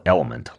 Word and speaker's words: LMNT: It's called LMNT. It's spelled element, like L-M-N, the letter T.